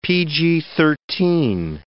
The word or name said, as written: PG-13